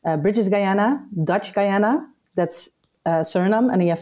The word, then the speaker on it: Suriname: In English, you would say Suriname, I guess, but in the Surinamese language, which is also Dutch, it mixed between Dutch and Creole, it's Suriname.